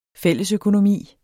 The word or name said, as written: fællesøkonomi